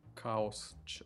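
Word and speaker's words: Chaos: Марков Чаус. Каос. Как читается? Каос, да. Наверное, Каос. Последнее.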